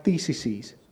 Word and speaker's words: theses: theses. Is that right? Theses. Did I say it right? Theses. Those are the words. Yeah, you guys are helping me.